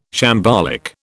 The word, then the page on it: shambolic: British English